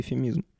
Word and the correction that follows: эвфемизм: Во-первых, я не могу никак запомнить, как произносится эфемизм. Я говорю то эфемизм, то эфемизм, и я не знаю, как правильно. – Я не могу загуглить. Эвфемизм. Эвфемизм. Эвфемизм.